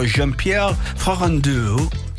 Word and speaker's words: Jean-Pierre Farandou: Il ministro del lavoro Jean-Pierre Farandou. – Sì. – L'ho detto bene, la Leti? – L'hai detto bene.